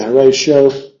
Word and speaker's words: Horatio: Pero el otro problema es si decimos Horatio en cuatro sílabas o Horatio en tres. Nos conviene decir Horatio, así podemos mantener el yambo, así que lo hacemos, porque podemos.